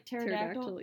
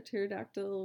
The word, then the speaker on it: pterodactyl: Why can't you hear a pterodactyl when they go to the bathroom? Because the pee is silent.